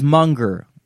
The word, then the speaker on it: Munger: The word is munger. So, how do you spell that? – M-U-N-G-E-R.